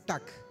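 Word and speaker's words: tack: Gracias es más fácil, es tac. Me escucha un sueco, me matan. Pues. tac. Ellos son como los finlandeses, acentúan las primeras sílabas y las otras no. Tac, es más fuerte.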